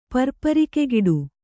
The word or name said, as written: ಪರ್ಪರಿಕೆಗಿಡು